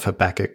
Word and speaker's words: Habakkuk: Now, I had to pause there for a second and make sure that I've got the pronunciation of Habakkuk correctly, because I'm not 100% sure on it. But Christopher and I both agreed that it's Habakkuk. – Well, that's based on the two Ks.